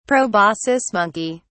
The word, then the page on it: proboscis monkey: English; British English